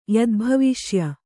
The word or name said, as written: ಯದ್ಭವಿಷ್ಯ